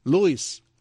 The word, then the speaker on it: Lois: Saltó a la fama esta actriz en el año 78 por hacer el papel de Louise. Lois Lane en Superman. ¿Cómo se pronuncia? Lois. Lois. Pero hay que poner la cara así, la boca, hay que echar para adelante así.